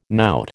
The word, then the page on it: knout: American English